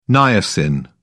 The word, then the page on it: niacin: British English